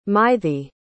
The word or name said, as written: mythy